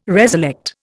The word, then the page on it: reselect: Indian